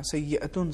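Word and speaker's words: سيئة: آپ نے تلفظ صحیح نہیں کیا پہلی آیت یعنی آیت نمبر 131 میں سیئتن شاید آپ نے کچھ اور پڑھا سیئتن صحیح ہے یعنی اس پر تنوینیں پیش ہیں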